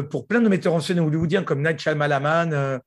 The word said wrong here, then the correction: M. Night Shyamalan: J'aime bien comment tu prononces le nom de M. – Night Shyamalan.